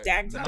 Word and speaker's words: Dagda: His name is Dagda. I am hoping I'm pronouncing that correct. – Yeah, Dagda. – Right. Dagda.